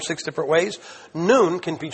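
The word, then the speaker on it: noon (νῦν): And here the Greek word is noon, nu epsilon nu, pronounced like high noon, and it means now.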